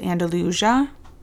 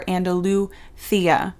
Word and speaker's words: Andalucía: And he lives in a region of Spain called Andalusia, which is the English pronunciation of the word, or Andalucía, which is supposedly the Spanish pronunciation of the region.